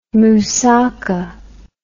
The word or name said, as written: mousaka